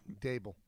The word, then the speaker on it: Daboll: How do you say his last name? – Dable.